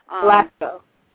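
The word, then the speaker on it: Blasco: Oh, and the other thing that I use a lot, and I've never switched, which is, and I'm going to say the name wrong, so please forgive me, because I add an extra syllable, is Joe Blaskow, or it's Blaskow, I can't say it correctly. – Blaskow.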